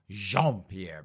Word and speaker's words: Jean-Pierre: Corrine, now did we say her name was Jean-Pierre or, oh, it was Jean-Pierre because that sounded fancier. It sounded French.